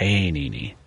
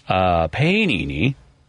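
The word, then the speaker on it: Pāṇini: Panini. – Yeah. That's my guess. Yeah. – It's a good guess. It's a long A sound. Yeah. – Mm-hmm. Panini wrote almost 4,000 verses describing rules of what are now fields of knowledge, such as syntax and semantics.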